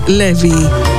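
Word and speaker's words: Laufey: Η Ισλανδοκινέζα Levy. Γράφεται Lofi, αλλά προφέρεται Levy.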